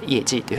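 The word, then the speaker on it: 业绩: 業績の行という字はイエと発音しまして、えー、席という字はですね、ジというふうに発音しますので、えー、業績はこの 2 つ合わせまして、イエジというふうに発音します。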